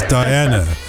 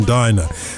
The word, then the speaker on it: Diana: It's Diana. I mean, musically, it's got to be Diana rather than Dinah. Dinah sounds as if you're about to go for a meal in a restaurant.